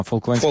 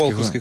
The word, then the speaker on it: Фолклендські: Ні, наголос на Фолклендські. – Фолклендські, звісно.